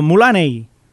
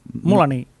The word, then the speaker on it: Mulaney: Más, eh, más en este caso, Mulaney. Mulaney. Mm. ¿Lo he dicho bien? – Mulaney.